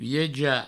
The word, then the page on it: viejar: vieja